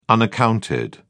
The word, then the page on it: unaccounted: British English